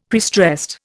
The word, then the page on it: prestressed: Indian